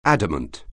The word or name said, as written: adamant